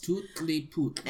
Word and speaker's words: Tutli-Putli: It's called Madame Tutli-Putli. – Oh. – It's pronounced how it's spelt. Spelt okay. – How it's pronounced. – Tutli-Putli.